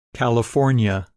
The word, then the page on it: California: English